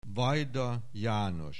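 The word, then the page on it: Vajda, János: Hungarian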